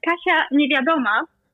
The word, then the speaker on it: Kasia Niewiadoma: also die Kasia, die heißt Kasia Niewiadoma.